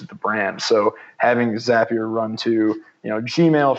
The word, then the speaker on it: Zapier: I think there's definitely two different camps of pronunciation on that software, but I go with Zapier. that software. – I think their slogan is Zapier makes you happier.